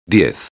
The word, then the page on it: 10: espanhol